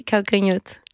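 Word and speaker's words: Calcanhotto: Alors j'espère bien prononcer son nom. Adriana, oui ? – Oui. – Calcanoto ? – Oui, Calcanoto.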